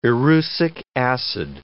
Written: erucic acid

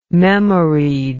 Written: memoried

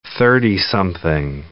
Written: thirtysomething

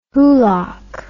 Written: hoolock

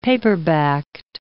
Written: paperbacked

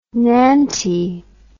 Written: nanty

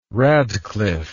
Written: radcliff

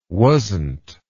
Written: wasnt

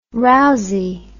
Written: rousie

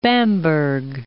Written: Bamberg

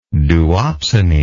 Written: duopsony

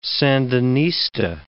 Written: Sandinista

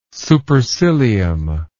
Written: supercilium

